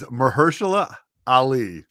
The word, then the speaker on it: Mahershala Ali: And that guy is Mahershala Ali.